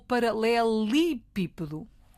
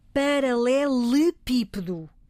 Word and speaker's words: paralelepípedo: Pronúncia paralelepípedo. E porquê? Temos dito várias vezes no nosso programa a forma como pronunciamos e como escrevemos as palavras decorre da sua origem.